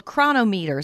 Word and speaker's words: Cronometer: It's called Chronometer. Is that how you say it? – Yes, and it's spelled C-R-O-N-O-M-E-T-E-R. It's not spelled quite as it sounds, but I think the app is a great tool.